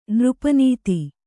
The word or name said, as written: ನೃಪನೀತಿ